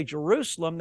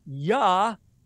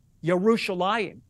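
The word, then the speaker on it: Yerushalayim (ירושלים): And the Hebrew letters don't say Jerusalem, they say Yerushalayim, Yerushalayim. So eventually, the way that the language has progressed, people began pronouncing the Yah sound in the original Hebrew with the J.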